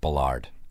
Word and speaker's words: Ballard: It's pronounced Ballard.